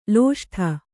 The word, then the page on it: ಲಿಸ್ಟು: lisṭu